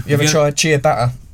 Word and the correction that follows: ciabatta: Yeah, no, garlic bread, isn't it? – No. – It's pronounced ciabatta.